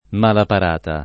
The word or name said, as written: mala parata